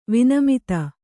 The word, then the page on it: ವಿನಮಿತೆ: vinamite